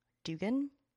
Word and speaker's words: Duggan: lisa dugan is how everyone else pronounces it i pronounce it duggan but i guess i'm i guess i'm just a rube or a plebe or whatever. Um, but I will be pronouncing it Duggan because I, I believe that's correct.